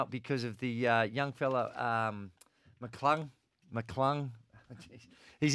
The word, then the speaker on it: McClung: I know it's McClung.